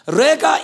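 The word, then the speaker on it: rega (רגע): We said that word little space is the Hebrew word R-E-G-A, pronounced rega.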